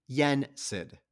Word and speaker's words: Yensid: Yensid.